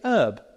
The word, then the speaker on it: herb: By the way, is it true that in America they say herb? – Yes. – So they don't spell it with a H, or is it silent H? – Silent H.